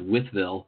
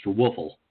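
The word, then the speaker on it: Wytheville: In a small town called Withville, which the locals pronounced Woofle.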